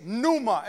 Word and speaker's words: pneuma: And in the Greek, which is the language which our New Testament is written, it's pneuma. Everybody shout pneuma. See, some of you were trying to pronounce it. You were like, pneuma? The P is silent, all right?